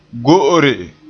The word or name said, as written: go'ore